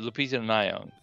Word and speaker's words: Lupita Nyong'o: And I think it's notable because only now in Hollywood are we seeing colors that are dark skinned black than the Nalupa Pianga. I can't even say her name. You know, the chick from Star Wars. I can't remember. – Lupita Nyong'o. – Yeah, thank you.